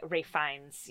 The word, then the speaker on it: Ralph Fiennes: that's how you say his name? – Yeah, Ralph. – It's not Ralph Phineas? – No, it's Ralph. Fuck. Ralph Fiennes.